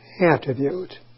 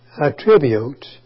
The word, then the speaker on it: attribute: However, we attribute, notice, word attribute is both a noun and is pronounced attribute. But it's also a verb, attribute.